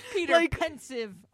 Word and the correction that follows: Pevensey: Hannah, how do you say their last name? – Pevensey. – Peter Pevensey.